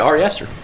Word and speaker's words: Ari Aster: But yeah, Ari Aster? – Yeah, Ari Aster.